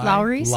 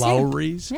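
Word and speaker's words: Lawry's: Am I saying it right? – L-A-W-R-Y. – Yeah. – Lowry's. – Lowry's. Yeah.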